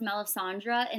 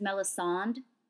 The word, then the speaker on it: Melisandre: So, like, Melisandre, we know, so I never know, I know her name is pronounced Melisandre in the show, but on the audiobooks that I listen to, the narrator says Melisande.